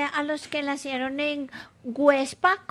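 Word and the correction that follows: Güepsa: Perdón, Huepsa. Websa. Con diéresis.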